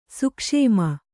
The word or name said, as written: ಸೂಕ್ಷುಮ